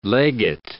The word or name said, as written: legate